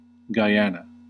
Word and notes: Guyana: /ɡaɪˈɑː.nə/; /ɡaɪˈæn.ə/

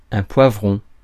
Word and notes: poivron: France; [pwa.vʁɔ̃]

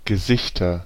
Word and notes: Gesichter: /ɡəˈzɪçtɐ/

